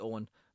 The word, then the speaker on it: Eoghain: If you're new to this and you're like, my name is spelled funny, it's actually Owen, like O-W-E-N and E-O-Y-N. It's just the Irish spelling of it.